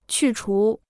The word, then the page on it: 去除: qù chú